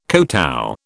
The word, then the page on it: kotow: British English